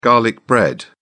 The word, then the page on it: garlic bread: British English